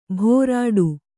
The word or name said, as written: ಭೋರಾಡು